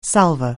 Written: salver

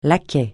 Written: laquais

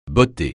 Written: botter

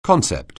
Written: concept

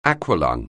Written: aqualung

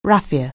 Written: raffia

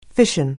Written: fission

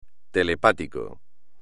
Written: telepático

